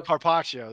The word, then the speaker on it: carpaccio: The second time I got surf and turf, but they have an appetizer that is octopus garpaccio. I can't say it right. – Carpaccio? – Carpaccio, that's it, yeah.